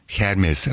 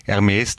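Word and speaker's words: Hermès: Dann kommt das, was Sie meinen, das Hermes, das ist der Franzose, ne? Der lässt das H aus, weil er es nicht aussprechen kann.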